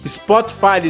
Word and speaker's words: Spotify: Nosso podcast ou no nosso espro... Isso, isso. – Spotify, Zezinho, Spotify.